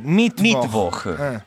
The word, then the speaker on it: Mittwoch: L'accento è all'inizio, Mittwoch.